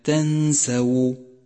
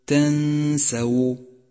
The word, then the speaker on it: تنسوا: خامسا احذر من اللحن في كلمة تنسو تنسو، فالسين مفتوحة والواو مضمومة فتلفظ هكذا: ولا تنسوا الفضل بينكم. ومن الخطأ أن تقول ولا تنسوا الفضل بينكم، فتنبه لذلك.